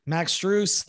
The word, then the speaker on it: Strus: Max Strus, that's how you pronounce it.